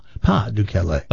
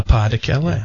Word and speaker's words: Pas de Calais: I'm glad you pronounced it right, because I always called it the Pas, but it's not. It's French, right? It's the Pas de Calais. – The Pas de Calais.